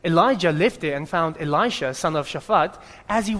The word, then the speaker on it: Elisha: Elisha.